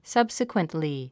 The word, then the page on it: subsequently: sʌ́bsikwəntli; サブスィクエントリィ